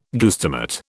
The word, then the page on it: guestimate: British English